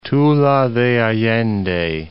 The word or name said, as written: Tula de Allende